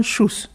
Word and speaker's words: Xousse: Zum Beispiel Schuss, das wird mit SCH ausgesprochen. – Und das Schuss schreibt sich? – X-O-U-S-S-E. – Und das spricht man dann Schuss.